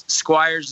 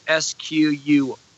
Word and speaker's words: Squyres: It's Squyres, is spelled Squyres.